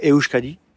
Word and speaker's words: Euskadi: la Copa Euskadi, si je prononce bien.